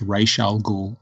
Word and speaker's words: Ra's al Ghul: I asked Denny O'Neill when I met him what the correct pronunciation was, and he said it's Ra's al Ghul.